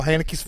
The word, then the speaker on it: Haneke: I refuse to say his name the way that they say it at the Academy. – Haneke.